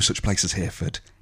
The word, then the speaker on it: Hereford: It's the bit where De Niro is talking to Sean Bean's character, and they're talking about an SAS training ground in Hereford, but Robert De Niro pronounces it as Hereford. – Oh. – And it annoys me that he gets it wrong,